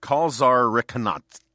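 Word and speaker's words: kalsarikännit: It is how I feel every New Year's Eve, is kalsarikanat, which is a butchered pronunciation of the Finnish word. It's kalsarika with an umlaut thingy, nnit.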